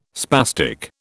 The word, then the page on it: spastic: British English